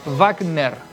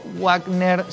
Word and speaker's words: Wagner: es con el sonido de la B corta al inicio con énfasis en la primera sílaba y solamente ner al final, no ener, sino que es Wagner, sea Wagner o Wagner sin la E intermedia.